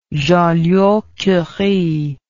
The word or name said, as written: Joliot-Curie